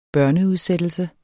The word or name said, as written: børneudsættelse